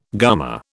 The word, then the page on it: gumma: British English